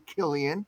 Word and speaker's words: Cillian: So the ones for the year was the actor who starred in Oppenheimer, whose name is Killian, but it's spelled C-I-L-L-I-A-N. And so a lot of people say it's Cillian, but it's actually Killian.